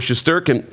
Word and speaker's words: Shesterkin: And news in New York, the Rangers are dealing with injuries, and the goaltender, Igor Shesterkin, how do you say his last name? – Shesterkin, yeah.